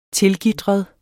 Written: tilgitret

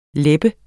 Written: lebbe

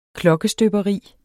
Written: klokkestøberi

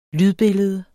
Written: lydbillede

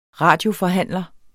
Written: radioforhandler

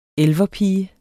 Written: elverpige

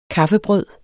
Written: kaffebrød

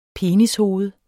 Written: penishoved

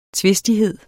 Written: tvistighed

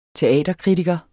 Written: teaterkritiker

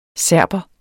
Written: SERBERE

